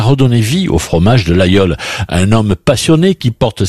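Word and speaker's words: Laguiole: Ça s'écrit l'Aïeul, mais on prononce l'Aïeul.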